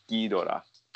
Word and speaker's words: Ghidorah: Is it Ghidorah or Ghidorah? You know, uh, pronouncing it the Japanese ways, it is Ghidorah.